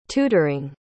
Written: tutoring

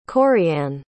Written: corrianne

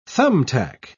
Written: thumbtack

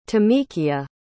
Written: tamekia

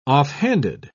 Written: offhanded

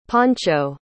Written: pancho

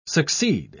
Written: succeed

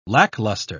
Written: lackluster

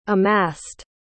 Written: amassed

